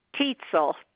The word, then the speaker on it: Tietzel: Phyllis Tietzel, T-I-E-T-Z-E-L. – Okay, how do you spell Phyllis? – P-H-Y-L-L-I-S.